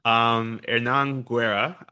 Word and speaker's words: Hernan Guerra: Hernan Guerra, apologies if I am grossly mispronouncing that, it's H-E-R-N-A-N, last name G-U-E-R-R-A.